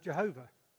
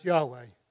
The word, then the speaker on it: Yahweh: So we used to think it was pronounced Jehovah. And you'll see various songs that have Jehovah. But actually, we now think it's pronounced Yahweh.